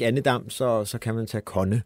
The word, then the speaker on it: Kone: Michel Nørgaard var jo inde på Kone, altså så, som også jo er elevator. Og som vist i øvrigt udtales Kone, kunne jeg sige, at der var nogen, der skrev.